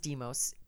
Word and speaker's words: DMOS: It is DMOS.